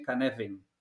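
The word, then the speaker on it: Cynefin: La salida directa de Canefin, yo se lo he oído a él pronunciar, es más bien Canefin.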